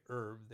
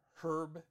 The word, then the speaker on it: herb: As when I teach this in Australia, uh, they would always, whenever I'd say herb, they'd say, um, Excuse me, do you mean herb? So then I go, No, I meant herb. so we have the silent H here.